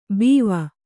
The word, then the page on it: ಬೀವ: Kannada